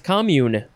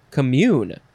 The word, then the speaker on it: commune: It's uh, let's see, we have two forms. The first form is pronounced commune because it is a verb and the second form is commune or commune. Uh, that because that is a noun.